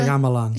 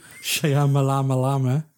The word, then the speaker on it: Shyamalan: Shyamalan. – Shyamalan. – Shyamalama.